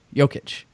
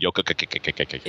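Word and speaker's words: Jokić: It's you just do not get the Eastern European soft C at the end. And you say Jokic instead of Jokic. Jokic.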